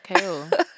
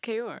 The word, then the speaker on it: Kaur: Yeah, Kaur. – Okay, yeah, Rupi Kaur. – I'm saying it like I know exactly how to say it. – I mean, you know, come on, it's Kaur.